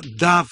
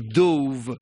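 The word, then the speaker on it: dove: Но английское существительное «дав», скажем так, да, которое пишется как «дов», значит, это голубь вообще.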